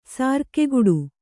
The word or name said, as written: ಸಾರ್ಕೆಗುಡು